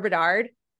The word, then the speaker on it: Bedard: Unless something goes awry with Connor Bedard.